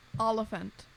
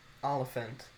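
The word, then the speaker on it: Olyphant: How do you pronounce it? – Oliphant. – Oliphant. – Like elephant, but with an ah.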